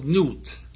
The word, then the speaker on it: Nut: Chapter 1 is the manifestation of Nut, usually pronounced N-U-T or N-U-I-T, from whence perhaps the French word for night, nuit, is derived, the well-known and very ancient Egyptian deity, the star goddess of the night sky.